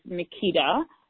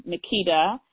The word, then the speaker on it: Makeda: And my email is Makeda, and that's M as in Mary, A-K-E, D as in David, A, Makeda, at Makeda Pennycook. And my last name is spelled exactly as it sounds. Penny, P-E-N-N-Y, C-O-O-K with an E at the end.